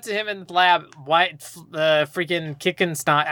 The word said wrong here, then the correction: Crickstein: I get him that wrong every time. – Holy shit. – Crickstein. – I said it wrong too. Crickstein.